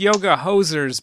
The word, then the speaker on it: Yoga Hosers: Because I think it's Yoga Hosers, but we keep saying Yoga Hosiers on this podcast. – Like pantyhose. – Oh, okay. – Yeah, it's pronounced like that.